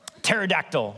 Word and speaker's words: Pterodactyl: Pterodactyl, like the P is silent though, you know?